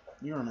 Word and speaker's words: Urim: But God, in dwelling with his people, made himself, you know, they had the Umum and the Thummim, or the Urim. Urim and Thummim, I always get mispronounced.